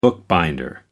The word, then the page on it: bookbinder: American English